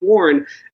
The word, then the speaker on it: Quorn: Quorn, I believe they do pronounce it without the Q-U sounding qua, so it's Quorn, not Quorn, is clearly the star of this company now.